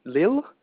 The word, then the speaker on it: Lille: Is it Lille? – Yep, you got it.